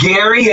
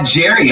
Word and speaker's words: Gerry: So it's Gary and not Jerry. I thought it was Jerry Gary. This whole time. – It's okay. Spelled Jerry, but it's pronounced Gary.